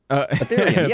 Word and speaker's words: Ethereum: Ethereum? – Ethereum.